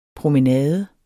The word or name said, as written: promenade